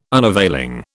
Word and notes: unavailing: British English

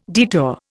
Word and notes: detour: Indian

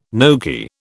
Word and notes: gnocchi: British English

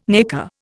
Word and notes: nacre: Indian

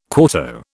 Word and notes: quarto: British English

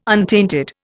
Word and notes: untainted: Indian

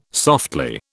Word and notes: softly: British English

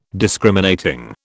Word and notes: Discriminating: British English

